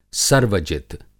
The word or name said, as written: सर्वजित